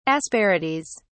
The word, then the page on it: asperities: British English